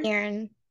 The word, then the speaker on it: Cairn: Cairn. C-A-I-R-N.